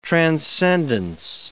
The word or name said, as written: transcendence